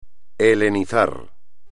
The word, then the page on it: helenizar: espagnol